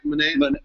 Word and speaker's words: Monáe: Monáe? – Yeah, the 18-year-old's nodding.